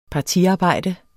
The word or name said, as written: partiarbejde